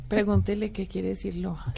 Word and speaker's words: LOHAS: Café Lojas se escribe L-O-H-A-S. – Café Lojas. Así es.